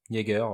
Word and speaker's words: Jaeger: Alors, Jaeger, c'est comme ça que ça se prononce ? – Jaeger, ouais, comme le Jaeger Master, je présume.